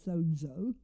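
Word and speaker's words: sozo (σῴζω): It's the Greek word sozo. You actually pronounce it sozo, and it means listen to what it means.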